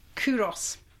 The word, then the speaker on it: Culross: So the first one we've got is called Kuros, which is spelt Culross in Scotland.